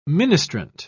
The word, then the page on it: ministrant: British English